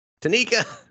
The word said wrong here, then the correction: Takina: Oh, Takina. – Takina.